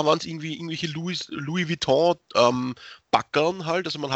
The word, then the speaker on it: Louis Vuitton: Äh, also wir würden es Louis Vuitton aussprechen. – Louis Vuitton, genau, danke.